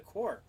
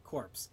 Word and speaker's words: Corps: It's the core. So, that was kind of funny, but I legitimately thought you pronounced that word corpse.